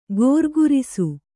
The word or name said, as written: ಘರ್ಘರಿಸು